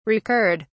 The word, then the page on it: recurred: British English